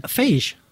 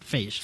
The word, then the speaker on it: Feige: Feige. It's like beige, right? – Until he comes on the show. – No, I know that's not how it's pronounced. – Feige.